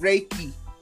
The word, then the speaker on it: Reiki: I always say this wrong, Reiki, is that right, Reiki? – Reiki.